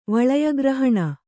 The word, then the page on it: ವಳಯಗ್ರಹಣ: vaḷaya grahaṇa